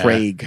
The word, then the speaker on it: Kreg: There was a running back on the Bucks years ago named Craig Lumpkin, but Craig was spelled K-R-E-G. – Pronounced Craig. Yeah.